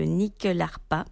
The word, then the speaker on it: nyckelharpa: Alors, attention à la prononciation, le nyckelharpa.